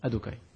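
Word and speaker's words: Adonai: Parce que Yud Kevavke, quand ces quatre lettres sont ensemble, ça ne se prononce pas. Ça se dit Adokai.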